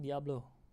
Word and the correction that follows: Diallo: Diallo. – Diallo, Diallo. D-I-A-L-L-O.